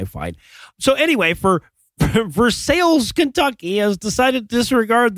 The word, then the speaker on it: Versailles: And in Rebuild That Wall news tonight, the town of Versailles, Kentucky, which, given the state it's in, is probably pronounced Versailles, has decided to disregard.